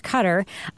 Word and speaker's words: Qatar: A physician from Qatar, or Qatar, as I used to pronounce it before I learned it was pronounced Qatar, wrote that he thinks it sounds like Bo has a high IQ, so is highly functioning on the spectrum.